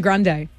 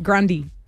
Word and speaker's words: Grande: Ariana Grande. Incorrect. – What is it? – Grundy. – Grundy. – Yep, she said that. She's confirmed it. It's actually Grundy.